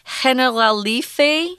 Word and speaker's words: Generalife: And they've got these gardens, uh, which have kind of a weird pronunciation. It's Spanish, so it's Generalife.